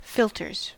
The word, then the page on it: filters: American English